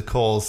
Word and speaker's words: Cowles: We forever having to teach people how to pronounce the name of this place because it's spelled Cowles. What looks like it should be Cowles is the Coles Center.